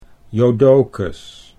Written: Jodocus

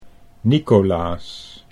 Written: Nicolaas

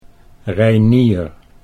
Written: Reinier

